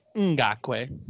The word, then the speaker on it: Ngakoue: I'm with you, the Yannick Ngakoue, and it's pronounced Ngakoue.